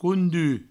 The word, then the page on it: conduch: coundu